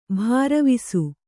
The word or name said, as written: ಭರವಸ